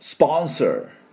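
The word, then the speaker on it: sponsor: でも、アメリカ人は、チュースで、スポンサーっていう音になります。 R をしっかり入れていきます。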